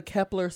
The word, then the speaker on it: Kepler: Wait, can you tell us about the Kepler Space Telescope? – Ooh.